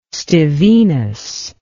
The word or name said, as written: Stevinus